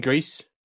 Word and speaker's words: Gris: I've been saying Gris, as in, like, you know, the movie. – Gris is the word. – That it is.